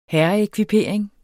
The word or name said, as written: herreekvipering